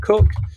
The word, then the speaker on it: Coke: There's a very famous jurist called Edmund Cook, whose name is spelt C-O-K-E, and therefore everybody calls him Edmund Coke. And if anybody says that, you need to, you need to glass him.